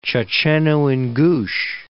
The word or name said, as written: Checheno-Ingush Republic